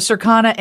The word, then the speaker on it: Circana: Marshal is the Chief Industry Advisor of Circana and retail. So, what is Circana, Marshal? And am I saying that right? – You you have it right.